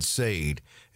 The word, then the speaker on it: Saide: I would suggest just searching Money Matters USA with Fred Sade. And Sade is spelled S-A-I-D-E.